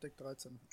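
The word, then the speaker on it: Deck13: Ich glaube, die spricht man auch Deutsch aus. – Tatsächlich? Hm. – Ich glaube ja. Ich glaube, man sagt Deck 13.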